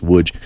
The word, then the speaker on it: Łódź: It's like L-O-D-Z, but it's pronounced Łódź.